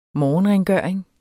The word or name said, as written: morgenrengøring